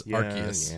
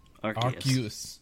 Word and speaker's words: Arceus: But then they put es on the end. That should change it. It would be a soft C then. – That's only if they're following English rules, and it's not made by an English company. The Japanese is Arceus.